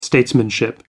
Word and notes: statesmanship: American English

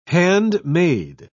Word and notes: handmaid: British English

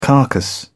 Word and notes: carcass: British English

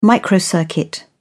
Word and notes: microcircuit: British English

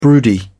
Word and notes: broody: British English

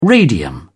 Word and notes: radium: British English

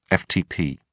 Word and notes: ftp: British English

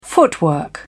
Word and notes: footwork: British English

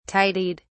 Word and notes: tidied: British English

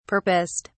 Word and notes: purposed: British English

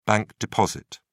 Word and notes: bank deposit: British English

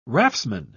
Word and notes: raftsman: British English

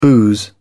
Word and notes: booze: British English

